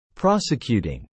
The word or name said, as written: Prosecuting